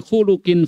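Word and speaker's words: khuluq (خُلُق): Khuluq ya. Bacanya khuluq, jangan khulqin. Ada khuluqun, ada khulqun. Khulqun fisik. Ya. Matanya, hidungnya, wajahnya, pipinya, telinganya, bibirnya, itu khulqun. Ya. Jadi kalau budi pengertinya, perangainya, namanya khuluq.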